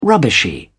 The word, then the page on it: rubbishy: British English